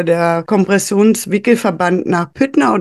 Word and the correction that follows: Pütter: Aber Pütter. Das macht man oft, Pütter, genau, verkehrt ausgesprochen, Pütter.